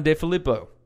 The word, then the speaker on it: DeFilippo: John DeFilippo.